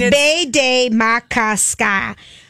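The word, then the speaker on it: Bde Maka Ska: Okay, which I think is maddening on all the signs because it's spelled B-D-E... M-A-K-A-S-K. – Bodie, it's Bay Day Makaska.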